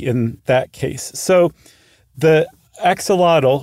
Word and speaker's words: axolotl: So the word axolotl, what we call axolotl, is actually axolotl, even though it's spelled the exact same way. In the Nahuatl language, A-T-L is pronounced lut. And X is very often pronounced show.